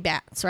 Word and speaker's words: Batts: Bats or Bates? – B-A-T-T-S. So that would be Bats, right? – Yeah, I guess.